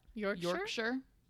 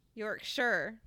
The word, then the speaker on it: Yorkshire: How do you pronounce that? – Yorkshire. – Yorkshire. Thank you. Shire is like Hobbit.